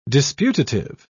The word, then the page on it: disputative: British English